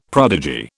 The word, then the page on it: prodigy: British English